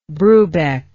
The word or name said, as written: Brubeck